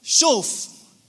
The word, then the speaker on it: shuv: In fact, it says, return, restore, is the same word, shuv, S-H-U-V. It's pronounced shuv.